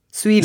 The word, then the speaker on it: Zuill: Quill. – Z-U-I-L-L. Eh, swill.